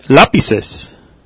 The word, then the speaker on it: lápices: Now, why is the accent on the A in lapices? You still hear the stress on the A and you still keep the accent.